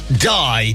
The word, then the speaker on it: Dai: It's called Die, Die. But actually, that's spelled D-A-I, and it's Italian slang, apparently, for come on, come on.